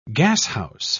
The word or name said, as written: gashouse